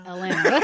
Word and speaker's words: Atlanta: Is it Atlanta or Atlanta? – Atlanta. – Drop the T's or whatever.